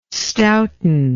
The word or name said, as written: stouten